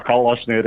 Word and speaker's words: калашный: Ну, ну, булочная, но сейчас вот в фразеологических оборотах осталось, например, со свиным рылом в калашный ряд. Э, ряд-то он калачный был, да. но произнесся калашный, до сих пор произносится калашный, хотя никаких калашных рядов уже нигде э, не осталось.